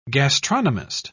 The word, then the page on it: gastronomist: British English